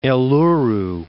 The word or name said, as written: Eluru